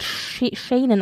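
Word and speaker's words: Senan: Also Dominic West hat ja Charles gespielt und sein Sohn, ich glaube, er wird Shanen ausgesprochen, Shanen West hat in diesem ersten Teil, also Folge 1 bis 4, eben William gespielt.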